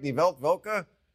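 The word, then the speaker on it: Die Weltwoche: Amy Holmes, did I say that right? Die Welt Woche? – Excellent pronunciation.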